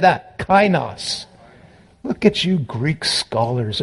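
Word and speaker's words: kainos (καινός): I had the wrong word or the wrong pronunciation the first service. Kainos, neos neos, two different words.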